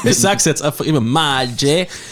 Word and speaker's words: Maggi: Gibt es einen Zusammenhang zwischen Maggi und Vor-Maggi? – Ich glaube nicht. Es ist nur die Doppel-G, wird so ausgesprochen. Es ist ein J im Italienischen.